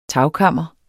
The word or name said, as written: TAGKAMRE